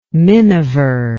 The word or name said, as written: minever